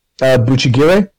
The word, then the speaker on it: Bucchigire: And this is samurai one that I didn't mention last time, called I think it's pronounced Buchigire. Okay.